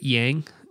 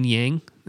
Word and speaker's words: Jiang: and I think I said that right, Yang, J-I-A-N-G, Lin Yang.